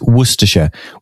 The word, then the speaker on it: Worcestershire: It's Worcestershire.